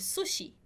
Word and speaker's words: sushi: Em espanhol, a gente fala sushi. A gente faz esse su com uma sílaba mais forte. El sushi, perfeito?